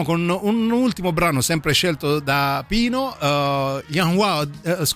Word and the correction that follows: Unwound: io non riesco a pronunciarli mai. – Unwound, vedi, dovevi stare tu al posto mio, io al posto tuo.